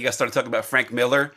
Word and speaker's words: Millar: You have the comic book writer Mark Millar, but it's actually Mark Miller. Just everyone calls him Millar.